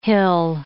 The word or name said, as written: hill